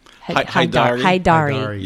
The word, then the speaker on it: Haydari: Is that how you say it? – Haydari.